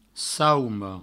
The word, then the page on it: sauma: saumo